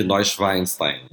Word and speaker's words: Neuschwanstein: É perto, perto de Munique, Neuschwanstein.